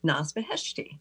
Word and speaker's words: Naz Beheshti: I'd like to welcome our guest today, Naz Beheshti.